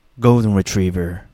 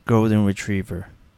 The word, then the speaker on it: golden retriever: あのアメリカ発音になると ver ver ver ね、 golden retriever、 golden retriever イギリス英語だと Golden Retriever。